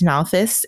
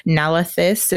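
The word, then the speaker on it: Nalthis: And they discuss some of Aelai's findings, like her notebook where it mentions Nalthus and Skadriel, which he corrects her Rosharan pronunciation, Nalathus and Skadariel.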